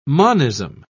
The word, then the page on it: monism: British English